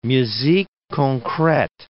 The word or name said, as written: musique concrete